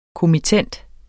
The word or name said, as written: kommittent